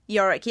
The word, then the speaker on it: Yorick: Which, of course, I had Yorick. Is that right? Did I say that right? – Yorick, yep.